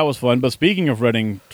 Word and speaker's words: Reading: Which I always had pronounced Reading because I just didn't know any better, but it is Reading.